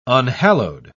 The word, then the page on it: unhallowed: British English